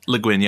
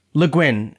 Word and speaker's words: Le Guin: Le Guin, yeah. – Le Guin.